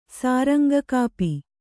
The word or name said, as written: ಸಾರಂಗಕಾಪಿ